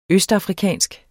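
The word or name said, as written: ØSTAFRIKANSKE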